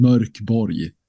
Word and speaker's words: Mörk Borg: It's you can pronounce it in a lot of different ways. Right. So we've learned the last couple of years. But in Swedish, I think it's intended to sound Mörk Borg.